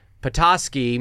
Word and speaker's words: Petoskey: They told me how to say this. Oh, like an octopus. Petoskey, Michigan, the 29th.